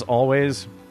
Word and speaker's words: Alvvays: They're known as Always, but spell it with double V's instead of a double U.